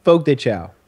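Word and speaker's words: Fogo de Chão: So I came into Fogo de Chao. Did I pronounce that right? – Fogo de Chao.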